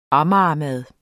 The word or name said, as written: amagermad